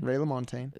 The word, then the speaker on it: Ray LaMontagne: Ray LaMontagne.